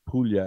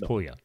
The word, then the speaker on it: Puglia: Yes, some people, it's also sometimes written as Apulia, A-P-U-L-I-A. But the formal name is Puglia, P-U-G-L-I-A. – Yes. – So it's pronounced Puglia as in million, not Puglia.